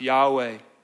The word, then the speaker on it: Yahweh: Probably closer to pronounce Yahweh.